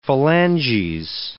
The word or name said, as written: phalanges